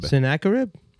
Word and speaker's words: Sennacherib: Sennacherib.